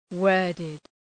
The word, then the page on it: worded: ‘wɜ:rdıd